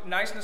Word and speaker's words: Knysna: It's spelled with a K, but it's a silent K. And Knysna,